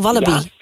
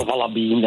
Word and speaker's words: walibi: Castratie Walibi, zeg je dat nou? Oh, Walibi, ja. – Ja, Walibi, het is dat je de klemtoon legt.